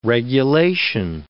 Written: regulation

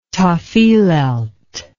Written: tafilelt

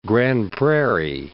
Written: Grande Prairie

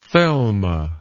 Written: thelma

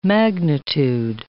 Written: magnitude

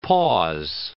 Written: pause

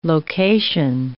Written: location

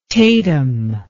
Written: Tatum

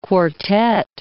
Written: quartette

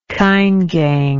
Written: caingang

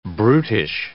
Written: brutish

